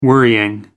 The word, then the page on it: worrying: American English